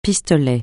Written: pistolet

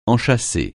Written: enchâsser

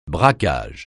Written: braquage

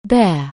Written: bear

